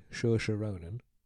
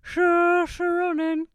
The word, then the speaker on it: Saoirse Ronan: I'd say Saoirse Ronan. – Oh, yeah. Saoirse Ronan.